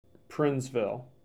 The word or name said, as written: Princeville